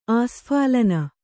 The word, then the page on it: ಆಸ್ಫಾಲನ: āsphālana